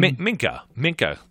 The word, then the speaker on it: Minkah: And, you know, if Minnick, how do you say his name? – Minkah. Come on, man. – Minkah. – It's like a mink coat.